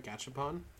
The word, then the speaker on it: Gachapon: There's like Gachapon. Am I saying that right? Gachapon.